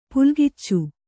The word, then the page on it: ಪುಲ್ಗಿಚ್ಚು: pulgiccu